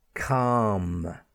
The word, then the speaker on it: calm: And like half, we have the young cow, calf, C-A-L-F, a newborn calf. Okay, and then before the letter M, we also have the silent L, bam, B-A-L-M, calm, C-A-L-M, the calm weather. And palm, the palm of your hand, P-A-L-M.